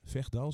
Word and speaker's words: Vechtdal: In het Vechtdal, zeg ik dat goed? – Ja, klopt.